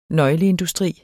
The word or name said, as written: nøgleindustri